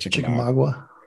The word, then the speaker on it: Chickamauga: Chickamauga, I think is how you say it. – Chickamauga. Yeah, chick. – I think chick is like the universally accepted name for that lake. So it's chick and then something.